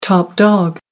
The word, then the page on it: top dog: American English